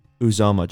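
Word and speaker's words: Uzomah: Felix Azuma. – Let me see. Felix Uzoma. Just say Felix Uzoma. – Okay. So the pick is in. The last pick of the first round. The Chiefs take Felix Uzoma, Kansas State.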